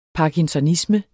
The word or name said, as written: parkinsonisme